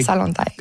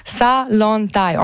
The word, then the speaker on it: Zalantay: Salantay. – Je vais me noter ça comme il faut. – Dites-le comme il faut pour l'apprendre. – Salantay. On ne prononce pas le Z.